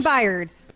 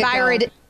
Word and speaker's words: Byard: Kevin Byard. Kevin Byard. – That's how you say it. Not how I'm saying it in that clip.